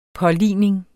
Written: påligning

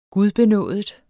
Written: gudbenådet

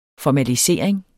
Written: formalisering

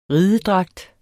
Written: ridedragt

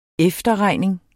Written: efterregning

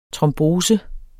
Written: trombose

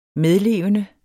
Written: medlevende

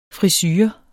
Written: FRISUREN

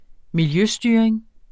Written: miljøstyring

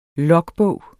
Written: logbog

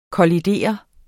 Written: kollidere

